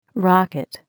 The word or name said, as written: rocket